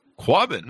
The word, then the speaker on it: Quabbin: The book is called Before the Flood, Destruction, Community, and Survival in the Drowned Towns of the Quabbin. I'm not sure. Did I pronounce that right, Elisabeth? – You did.